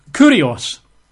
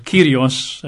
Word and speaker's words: kurios: The Greek word is kurios. K-U-R-I-O-S. Kurios. K-U-R-I-O-S. I think it's pronounced kyrios in modern Greek and probably original Greek, but kurios, kyrios.